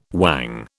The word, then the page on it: whang: British English